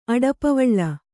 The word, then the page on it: ಅಡಪವಳ: aḍapavaḷa